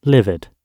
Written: livid

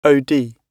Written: od

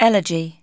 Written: elegy

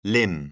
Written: limn

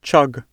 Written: chug

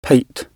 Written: pate

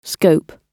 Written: scope

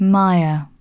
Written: mire

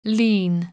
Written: lien

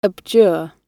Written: abjure

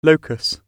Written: locus

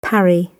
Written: parry

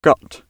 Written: gut

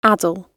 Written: addle

